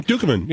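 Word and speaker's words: Ducommun: I don't know if this guy's alive or dead, and I think I'm going to butcher his last name, but Rick Dukeman? Mm-hmm. – Dukeman, yeah.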